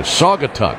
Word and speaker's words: Saugatuck: I think I'm saying it right. Saugatuck. S-A-U-G-A-T-U-C-K.